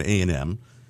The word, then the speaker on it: A&M: So Jack Isquith, who's the head of alternative promotion at A&M Records. – Oh, is that how you pronounce that? A&M? – A&M? – Yeah. – Another meeting. – A&M.